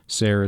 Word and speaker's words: Sayre: Zelda Sayre Fitzgerald, July 24, 1900 to March 10, 1948, born Zelda Sayre, Sayre is pronounced to rhyme with fair, in Montgomery, Alabama, was an American novelist and the wife of writer F.